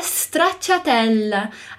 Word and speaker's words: stracciatella: Observe aqui que o S no início ele é mudo. Não digo stracciatella, mas stracciatella. Repete anche tu, repete você também.